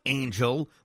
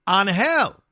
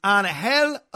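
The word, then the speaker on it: Ángel: It's not Angel like the team that lost Otani. It's pronounced Angel. Luis Angel Acuna, all the love.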